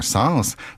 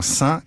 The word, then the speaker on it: sens: Il suffit de penser à l'expression synonyme « ça n'a aucun sens » dans laquelle l'S final de « sens » est bien sonore. La prononciation « sans » est vieillie.